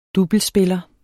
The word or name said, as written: doublespiller